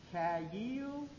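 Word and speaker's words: kayil (חיל): It is, the pronunciation is, it is kayil.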